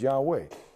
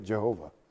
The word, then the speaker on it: Yahweh: When you lengthen it out, the best we know it can be is Yahweh. Can't be three syllables, because in Hebrew you can't do that. You can't make it Jehovah. That's an impossibility in the grammar of the Hebrew.